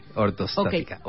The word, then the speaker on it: ortostática: El síncope cardíaco, el síncope reflejo, y la hipotensión ortotástica. – ¿Lo dije bien? ortostática, ortostática. Ortostática.